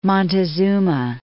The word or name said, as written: Montezuma II